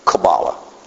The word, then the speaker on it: Kabbalah: In Hebrew, it's more of a Q sound. Kabbalah.